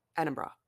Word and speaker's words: Edinburgh: It's bra at the end. Like B-R-A. Edinburgh.